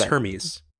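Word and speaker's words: Hermès: I pronounced it correctly there, didn't I? Hermes? – No, it's Hermes.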